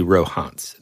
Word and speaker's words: Rohonc: Okay, now, when you look this up, this book, the way it's spelled is R-O-H-O-N-C. So whenever I found out about this book, I thought it was the Rohans Codex, right? Mm-hmm. But it's actually Rohans, and that's how you pronounce it.